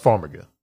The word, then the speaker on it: Farmiga: I thought it was Farminga. – No, it's Farmiga. – Vera Farmiga. Okay. – Which, yeah, whatever. – I could have swore there was an N.